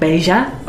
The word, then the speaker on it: bella: Because in Argentinian Spanish, bella, the word for beautiful, is pronounced more like beja.